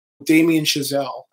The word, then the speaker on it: Chazelle: Damien Chazelle. – Okay. – Rhymes with gazelle.